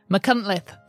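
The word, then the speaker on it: Machynlleth: Thanks to Beth, who is one of our Welsh patrons, who corrected me on my pronunciation of McCuntlith. That's what it was.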